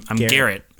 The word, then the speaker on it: Garrett: And people always think I'm saying Gary. – Oh, hey, Gary. – It's like, no, I'm Garrett.